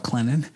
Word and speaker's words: Clinton: I did my pharmacy school at Presbyterian College down in Clinton, South Carolina, and for those who's there, they don't pronounce the T, they call it Clinton.